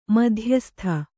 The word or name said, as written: ಮಧ್ಯಸ್ತ